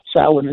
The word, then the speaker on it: Samhain: Now, that is spelled Samhain, but it's pronounced Samhain. It's a Gaelic word, it's a foreign word, so the M and the H are silent, and it comes out sounding Samhain.